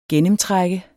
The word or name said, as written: gennemtræk